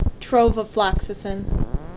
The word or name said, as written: Trovafloxacin